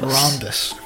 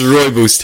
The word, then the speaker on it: rooibos: But it's, rooibos. It's spelled R-O-O-I-B-O-S. Rooibos. – Rhombus. – Rooibos. – Rooibus? – Rooibus. – Rooibus. – Actually, don't say bus, because that's the wrong pronunciation.